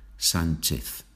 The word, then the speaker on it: Sánchez: We said that the H is not pronounced in Spanish. Obviously, when you have CH, then we say Che, Sánchez, Chez, Sánchez.